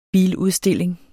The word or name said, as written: biludstilling